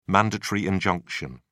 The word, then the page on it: mandatory injunction: British English